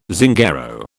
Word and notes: Zingaro: British English

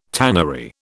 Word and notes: tannery: British English

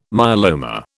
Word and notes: myeloma: British English

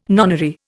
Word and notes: nonary: Indian

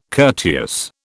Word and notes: courteous: British English

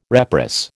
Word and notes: reprice: American English

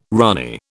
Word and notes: ranee: British English

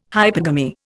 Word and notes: hypergamy: Indian

